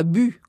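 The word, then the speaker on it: Bult: Vous vous, prononcez bu. Hein. Le L et le T euh, sont muets, on va le dire comme ça.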